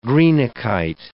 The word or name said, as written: greenockite